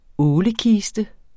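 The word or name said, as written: ÅLEKISTEN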